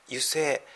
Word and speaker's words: 油井: これで油性。石油の井戸って油井って読むんだと思ったら油性って読むんだそうですね。